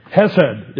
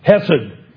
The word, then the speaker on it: hesed: Hesed is how it's pronounced or transliterated. Hesed.